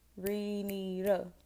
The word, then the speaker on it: Renita: So my name is Renita. You don't need the last name because people don't need to be finding me. You know what I'm saying? So say it with me. Renita. Three syllables.